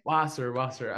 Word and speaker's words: Wasser: Wasser.